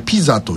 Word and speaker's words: PISA: 先進国 34 カ国が各国の 15 歳を対象に 3 年ごとに実施している学習到達度に関する国際調査、通称 PISA と書いてピザというものだそうです。